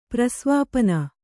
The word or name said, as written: ಪ್ರಸ್ವಾಪನ